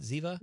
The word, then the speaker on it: Ziva: Am I saying that right? Ziva? – You are saying that right.